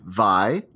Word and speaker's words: Wai: Hawaiian pronunciation, Vi, like Count Dracula saying Y. Oh, so I even did it wrong. Vi, like Count Dracula saying Y, followed by a glottal stop, then Allah, like the French Allah, blended at the end with an unsure eh.